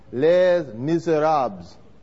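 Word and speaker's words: Les Misérables: Did I pronounce that right? Where's our French wheel? Les Miserables. Wait, say that one more time. Les Miserables. That's close enough.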